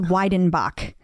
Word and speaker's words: Weidenbach: I think it's pronounced Weidenbach.